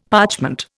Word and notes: parchment: Indian

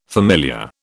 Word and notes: familiar: British English

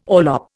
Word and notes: orlop: Indian

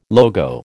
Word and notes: logo: American English

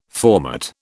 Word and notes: formate: British English

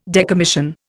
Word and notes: decommission: Indian

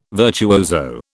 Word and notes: virtuoso: British English

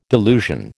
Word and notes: delusion: American English

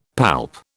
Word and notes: palp: British English

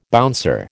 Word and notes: bouncer: American English

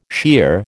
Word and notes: shear: American English

